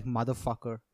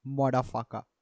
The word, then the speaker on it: modafucka: And it's not like motherfucker. It's modafucker. Like, it's spelled M-O-D-A-F-U-C-K-A.